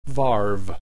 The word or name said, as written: varve